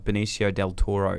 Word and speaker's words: Benicio Del Toro: I haven't seen Reptile yet, but I do really love Benicio Del Toro.